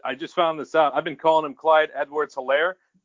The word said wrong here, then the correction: Edwards-Helaire: I guess the H is silent. It's Clyde Edwards-Helaire.